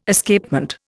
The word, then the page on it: Escapement: Indian